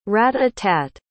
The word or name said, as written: rat-a-tat